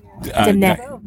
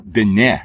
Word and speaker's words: Diné: So the language is, you say it's Diné. It's spelled like dine. – Right, with but it's pronounced high E at the end. – Diné. Diné.